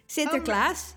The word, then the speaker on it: Sinterklaas: Sinterklaas, yeah.